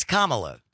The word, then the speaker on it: Kamala: It's Kamala. – There's two ways to pronounce the name. There's one correct way for Kamala Harris. That's it.